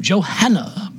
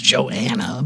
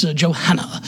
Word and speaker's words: Johanna: Oh, Johanna. Yes, the H is pronounced in England. America, they just got rid of it. It's Joanna, but here it's Johanna.